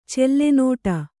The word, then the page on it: ಚೆಲ್ಲೆನೋಟ: kannada